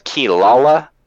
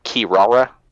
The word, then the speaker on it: Kirara: And the flying fox demon, they say it as, they pronounce it as Kilala in the anime dub version, but it reads like Kirara.